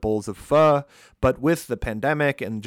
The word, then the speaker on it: pho: So Pho, P P-H-O, pronounced pho, is a soup that comes from Vietnam, but has kind of made its way throughout Southeastern Asia.